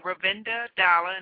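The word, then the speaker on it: Ravenda: Make sure you put my name, Ravinda Dalla, and it's spelled R-A-V-E-N-D-A, D-A-L-L-A-H,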